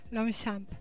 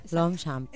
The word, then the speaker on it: Longchamp: Porque no se pronuncia como se escribe. ¿Se pronuncia? – Longchamp. – Longchamp.